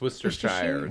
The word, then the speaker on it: Worcestershire: It's Worcestershire. Worcestershire. That's how you pronounce the sauce.